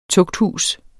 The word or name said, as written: tugthus